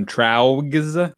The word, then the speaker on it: Traugs: Traugs? Is that it? – Traugs, yeah. – Yeah, they're. – No, I believe you said it right the first time. Traugs. That sounds correct.